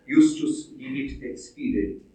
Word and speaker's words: Vivit: Das Akzent für Professor Burger dürfte nicht auf Fides gesetzt, da Glaube in diesem Sinne nur als Instrument dient. Richtig gestellt fällt die Betonung auf Vivit, da Leben das Ende und Zweck sei.